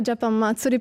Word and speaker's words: Japan Matsuri: La prima domanda è: si dice Japan Matsuri o ho sbagliato a mettere l'accento? – Io dico Japan Matsuri, però alla fine potete dirlo come volete.